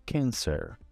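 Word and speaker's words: Cancer: E a pronúncia é a mesma coisa do abre a boca para dizer um A e de um E. Câncer. Câncer.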